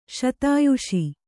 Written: ಶತಾಯುಷಿ

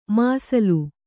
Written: ಮಸಲಾ

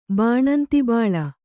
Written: ಬಾಣಂತಿಬೋಳ

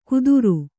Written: ಕುತ್ತುರು

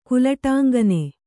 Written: ಕುಲಟಾಂಗನೆ

